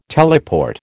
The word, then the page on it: teleport: American English